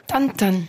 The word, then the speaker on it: Tintin: J'en reviens juste à mon ten-ten. On prononcerait comme ça ? Vous diriez comment, vous ? – Tintin.